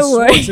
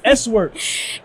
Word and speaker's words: S-Works: I've been saying Swartz. It is S-Works. – It's S-Works because it used to have a dash.